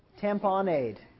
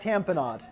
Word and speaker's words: tamponade: Tampon aid, but it's pronounced cardiac tamponade. It's kind of a British pronunciation.